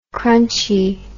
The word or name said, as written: crunchie